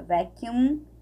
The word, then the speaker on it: vacuum: I wanna be your vacuum, vacuum, ó, tem M, a gente tem que fechar aqui, ó, vacuum cleaner.